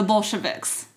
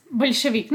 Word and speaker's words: Bolshevik: The Bolsheviks. – Bolshevik. No, they, Bolshevik. That's correct.